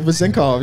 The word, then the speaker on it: Vezenkov: So Vezenkov, yeah.